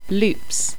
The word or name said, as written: Lupes